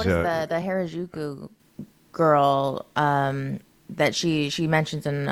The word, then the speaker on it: Harajuku: How do you, it's spelled H A R A J U K U Harajuku.